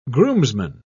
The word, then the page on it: groomsman: British English